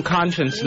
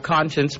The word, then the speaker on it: Konshens: I'm going to play some Conscience next. You know, an artist that's also on the show. And that's spelled K-O-N-S-H-E-N-S, in case you never know.